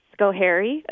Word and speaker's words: Schoharie: He is an assemblyman from Schoharie. Again, hope I'm saying that right. – Your favorite place, yes.